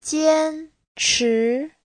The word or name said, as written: jian1chi2